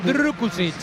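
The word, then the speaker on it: Drkušić: Hay que pronunciarlo Drukušić.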